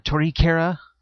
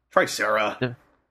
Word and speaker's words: Tricera: And since I was going for Candroids, I ended up picking up Kujaku and Tricera or Torikera. – Tricera. – I know. I know. That's just how it's pronounced.